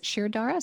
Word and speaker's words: Shirodhara: I had is it Shirodhara? Is that how it's pronounced? Mm-hmm.